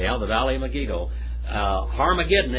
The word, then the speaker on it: Harmageddon: The word Armageddon. Harmageddon is how you say it, actually.